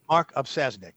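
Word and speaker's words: Opsasnick: Mark Opisasnik. – Mark Opisasnik. – Opisasnik. I was close.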